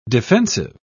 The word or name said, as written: defensive